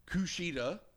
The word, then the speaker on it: Kushida: Kushida.